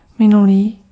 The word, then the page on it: minulý: /ˈmɪ.nu.liː/